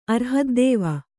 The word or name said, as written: ಅರ್ಹದ್ದೇವ